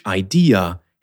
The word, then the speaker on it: idea: The issue here has to do with the fact that the French idée is a two-syllable word, while the English idea has three syllables, and there are no shortcuts here. What's interesting and completely logical is that French speakers of English tend to say ID when translating idée. But ID is already taken.